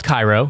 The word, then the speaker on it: Cairo: connects with the Ohio River in a town called Cairo, Illinois, spelled Cairo, it's pronounced Cairo, like many towns in the Midwest you will find, like Versailles, Indiana, spelled Versailles, pronounced Versailles.